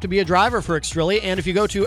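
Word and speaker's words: Xtrilli: Welcome Extrilli to the show. And again, it's X-T-R-I-L-L-I. I did have to check with them to make sure I was pronouncing it correctly.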